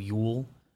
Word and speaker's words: Llull: Yeah, the basketball equivalent is Sergio Yule. L-L-U-L-L is how his last name is spelled.